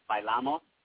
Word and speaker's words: bailamos: Well, it's funny because that song, like, the way you pronounce the word by itself, when you say it, is different than the way that he sings it. Because, like, the word, it means, like, we dance. But you would pronounce it bailamos. Like, that's how you would say, like, an accent over the A, that first A, like, the bailamos. So that's how you say the word.